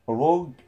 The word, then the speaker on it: rogue: طيب بيقول لك that اوف ذا روج هيرو، بيقول لك هذا البطل المحتال روج هيرو. خلي بالك روج بعديها يو اي، لا ينطق او لا ينطق لا ي... لا... لا تنطقان لا ينطقان هذان الحرفين.